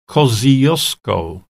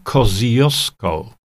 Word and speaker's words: Kosciuszko: E, Australijczycy e, wymawiają to jako, e, jako Mount Kosciuszko. Jeszcze raz wam powtórzę. W języku angielskim Australii góra Kościuszki to Mount Kosciuszko.